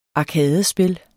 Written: arkadespil

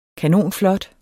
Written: kanonflot

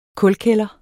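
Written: kulkælder

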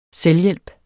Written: selvhjælp

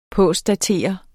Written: postdatere

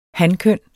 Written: hankøn

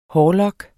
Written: hårlok

